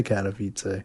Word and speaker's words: Katowice: Katowice.